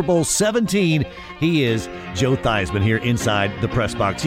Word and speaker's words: Theismann: They wanted to do the rhyme. – Yeah. – Theismann for Heisman.